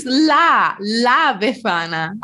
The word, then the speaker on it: La Befana: and I cannot for the life of me pronounce Le Befana. – Correct. Oh, it's La Befana.